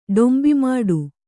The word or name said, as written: ಡೊಂಬಿಮಾಡು